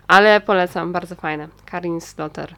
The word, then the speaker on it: Slaughter: Slaughter, Slaughter, nie wiem jak się wymawia. Slaughter!